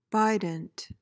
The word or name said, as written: bident